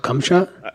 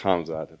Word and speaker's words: Khamzat: What's the proper way to say his name then? It's not Khamzat? – I don't think so. Some of those are a little tricky, I know, but yeah. I think it's Hamzat, I believe.